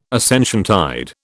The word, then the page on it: Ascensiontide: British English